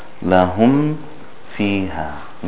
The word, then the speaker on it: lahum fiha: The meem, is there any hiding of the meem in the fa? No. It is pronounced clearly, pronounced with izhar. For example, lahum fiha. Lahum fiha. There's no hiding like we had before.